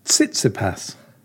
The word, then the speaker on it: Tsitsipas: Can you remember what it is? – Stefanos Tsitsipas. – Good attempt. But I just thought we would... – Perfect attempt. – Yeah, perfect attempt.